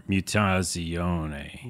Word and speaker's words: Mutazione: And by the way, you're saying it, I guess, correctly, because I didn't know how to say that either. Mutazione. – They tweeted a couple days ago what the official pronunciation was, and I double-checked that. So that is the pronunciation.